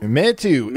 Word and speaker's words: Meitu: Meitu. – Meitu.